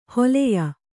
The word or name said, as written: ಹಲ್ಯ